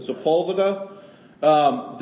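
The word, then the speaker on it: Sepúlveda: In 1534, a series of letters between Erasmus and his friend, how do you say this? Spultuvia? – Sepulveda.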